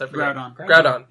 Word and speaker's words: Groudon: Groudon, yes.